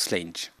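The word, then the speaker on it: Sláinte: Ce qu'on dit en écossais, c'est slainge. Et ça s'écrit Slainge. Et ça se prononce slainge.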